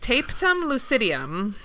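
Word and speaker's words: tapetum lucidum: Tapetum lucidium.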